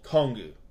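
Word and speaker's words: Kongu (コング): In Japanese, Kong is actually called Kongu, mm-hmm.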